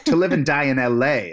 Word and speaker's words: To Live and Die in L.A.: Why did I say it like that? To Live and Die in L.A. To Live and Die in L.A. is obviously the way you're supposed to say it.